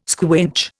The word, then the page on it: squinch: Indian